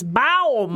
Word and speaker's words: Baum: Am I saying that right? – I think it's Baum.